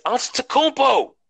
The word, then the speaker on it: Antetokounmpo: That's a lot of practice because Every time I say it wrong, hit my oldest son come, Daddy, it's Antetokounmpo. I'm like, yeah, you know what I said.